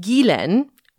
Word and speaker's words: Ghislaine: The closest I can suggest is Gilen, where the first syllable is pronounced like the Indian butter, and the second is pronounced, well, like Len. The emphasis is on the second syllable.